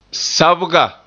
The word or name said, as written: sabga